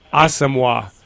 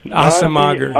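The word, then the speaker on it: Asomugha: Asamoah, Nnamdi Asamoah, Asamoah. Asamoah.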